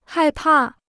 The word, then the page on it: 害怕: hài pà